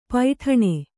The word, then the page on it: ಪೈಠಣೆ: paiṭhaṇe